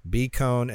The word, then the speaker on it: Kohn: Bcone, and it's spelled K-O-H-N.